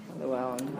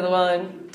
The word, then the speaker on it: Llewellyn: Llewellyn. – Four L's. – Four L's. – Roger Llewellyn. Double L at the beginning, double L in the middle.